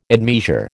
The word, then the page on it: admeasure: American English